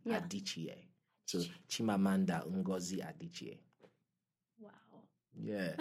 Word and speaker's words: Chimamanda: So it's Chimamanda.